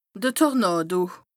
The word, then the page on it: de Tornàdo: Bas Rhin; Strasbourg